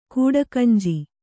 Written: ಕಡೆಕಂಜಿ

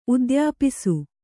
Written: ಉದ್ಯಾಪಿಸು

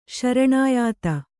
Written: ಶರಣಾಯಾತ

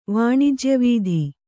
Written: ವಾಣಿಜ್ಯವೀಧಿ

